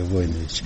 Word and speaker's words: Войнович: Есть какие-то семейные предания, и есть у меня книга, между прочим, из Сербии, наш род Войнович, правильное ударение Войнович.